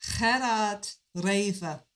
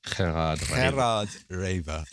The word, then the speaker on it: Gerard Reve: Gerard Reve. – Gerard Reve. – Yeah. Never going to be. – I don't think it's really going to help. I think if you go with Gerard Reve, that's probably close.